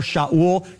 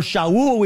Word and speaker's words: Shaul: So Paul never had his name changed from Saul or Shaul to Paul. It just said he had a Hebrew name, Saul or Shaul, we would pronounce it in Hebrew, and he had a Greek name, Paul.